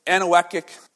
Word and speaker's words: anechoic: But it's called anechoic chamber. It means no echo. Anechoic. That's how you say it.